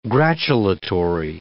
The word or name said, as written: gratulatory